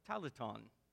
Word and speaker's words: talenton: In the Greek, there is a word that is pronounced talenton, and that is where we get the word in this story, talents.